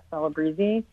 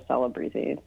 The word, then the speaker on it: Celebrezze: So I say my name is Kat Celebreeze, but when my grandfather came through Ellis Island, it used to be Celebreeze, but they changed all the I's to E's, and then it was kind of weirdly Americanized to Celebreeze. So that's how I pronounce it.